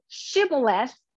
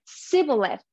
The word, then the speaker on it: shibboleth: And they picked that word because people here pronounced it shibboleth, but people from this group could not pronounce it that way. They said sibboleth. It's a minor difference, but a difference.